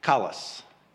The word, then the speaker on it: kalos: The second Greek term, translated into English, is spelled K-A-L-O-S, kalos. K-A-L-O-S.